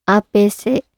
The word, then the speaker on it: A.P.C.: ちなみに洋服に関してですね、こんなランキングがありました。初見では読めなさそうファッションブランドランキング。3位、アーペーセ A.P.C.